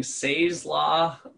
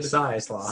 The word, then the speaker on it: Say's Law: I always said it as S-A-Y, Say's Law, but you said Size Law. – Size Law, like S-I-Z-E.